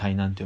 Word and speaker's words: 台南: 俺ね台湾ならねあのあたこの話もした気がする台南に行きたいって言ったっけそれはしてないと思ううんあの台北でタイに来たじゃんうん台南って台あの台に南って書いて台南って読むんだけど、